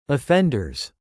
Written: offenders

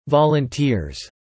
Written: volunteers

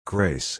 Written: grace

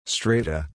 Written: strata

